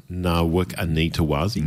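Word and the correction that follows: Nawaqanitawase: No, the Q is actually pronounced as an NG, so it's Mark Wananga.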